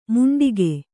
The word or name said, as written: ಮುಂಡಿಗೆ